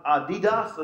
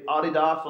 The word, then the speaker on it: Adidas: Well, there's a label, and I'm sure if we didn't have Adidas, as the Americans say, or as we say, Adidas on the bottom of it, we would realize the logo.